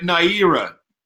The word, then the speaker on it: Naira: Like Euro, but Naira.